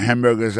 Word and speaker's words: hamburger: N'a pas remarqué que dans mot hamburger, ça, comme t'as dit, il y a trois syllabes là-dedans, coto appuyé, puis plus ça, c'est sous premier syllabe là.